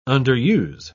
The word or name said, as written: underuse